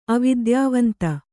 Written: ಅವಿದ್ಯಾವಂತೆ

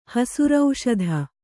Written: ಹಸುರೌಷಧ

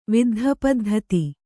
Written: ವಿದ್ಧಪದ್ಧತಿ

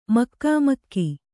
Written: ಮುಖಾಮುಖಿ

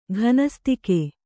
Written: ಘನಸ್ತಿಕೆ

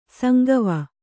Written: ಸಂಗವ

